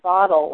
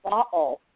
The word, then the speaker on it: bottle: My grandmother, Boston Irish, Charlestown Irish, she said, she didn't say bottle, like a lot of Americans say bottle with this tap D in the middle, and butter. She said bottle. So the middle of the word bottle for her was what's called a glottal stop.